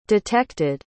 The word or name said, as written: detected